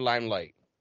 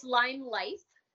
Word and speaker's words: Lime Life: Lime Life or Limelight? – Yep, it's Lime Life.